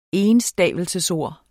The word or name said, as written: ENSTAVELSESORD